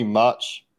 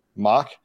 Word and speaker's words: Mauch: Cody Mauch, Mau, not actually sure how his last name is pronounced. – I think it's Mock, right? – Mock. Both of my guesses were wrong.